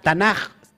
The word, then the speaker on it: Tanaj: Pero Tanaj se escribe así. Taf, Nun, Kaf. Tanaj.